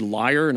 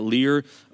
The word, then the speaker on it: lyre: and they've got I think I mispronounced it. It's actually lyre, not lyre.